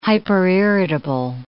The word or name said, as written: hyperirritable